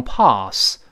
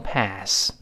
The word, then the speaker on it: pass: go by 是一个小的习语儿，它的意思就表示时间过去了，哎，时间流淌过去了，就类似于我们直接用动词 pass，p a s s，但是英国人读成 pass，美国人读成 pass，那。